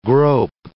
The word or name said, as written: grope